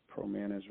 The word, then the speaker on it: Promanis: Promanis was just this acronym I came up with for professional management and acquisitions. So it's PROMANAS, like Sam on the end. Promanis rhymes with bananas.